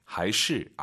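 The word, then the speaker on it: 还是: 이게 재밌는 게 하이시 아닙니다. 경성이에요. 시가 하이시 이성경성.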